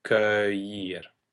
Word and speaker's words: cueillir: One should really think that the word should be spelled C-U-E-U-I-L-L to make more sense of it, mm-hmm. – because the U-E functions both as the hardening of the K device, but also as a combination E-U that we pronounce as an O-E in French. So cueillir.